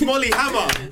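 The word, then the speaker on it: Molly Hammar: it's Molly Hammer.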